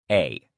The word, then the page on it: a: male